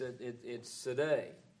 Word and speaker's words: Tzaddi: You see T-Z-A-D-D-I. The way my pronouncer said, it's Sadai.